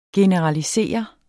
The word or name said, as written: generalisere